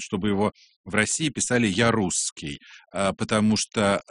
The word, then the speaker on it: Жарусский: И так они записали это в его документы, но по-французски это произносится Жарусский. И вот с тех пор так семья носит такую замечательную фамилию. Но все-таки мы будем его называть по-французски Жарусский.